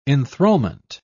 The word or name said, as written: enthrallment